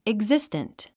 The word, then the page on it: existent: iɡzístənt; イグジステント